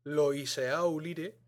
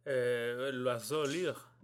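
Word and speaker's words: L'Oiseau-Lyre: Un disco del New London Consort dirigido por Philip Pickett y que está grabado bajo el sello tal cual se lee. Loiseau Lire, pero que al parecer, se pronuncia Loiseau Lire.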